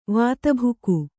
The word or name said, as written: ವಾತಭುಕು